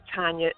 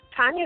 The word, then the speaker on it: Tanya: And then on Twitter, of course, I'm at Tanya D as in Dog Lewis. And keep in mind, Tanya is actually a Russian name.